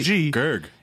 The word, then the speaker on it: GERG: Congrats on the record, and how do you say it? – How do I say it? – I know how to spell it, G-E-R-G. – Oh, right. GERG. – It is just GERG. – Yeah, it's just GERG.